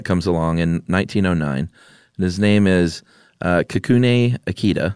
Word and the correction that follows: Kikune: There's like an extra little half syllable in there. Kikune.